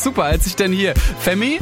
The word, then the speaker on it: F3MIII: It's pronounced Femi, weil er wird halt ganz anders geschrieben. Er wird so geschrieben F-3-M-I-I-I. Da weiß man jetzt nicht direkt, wie man das ausspricht, aber Femi.